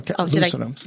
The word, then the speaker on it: lucidum: Tapetum lucidum. So that is... What? You're giving me a weird look. – No. Lucidum. – Lucidum. Sorry, I said it wrong.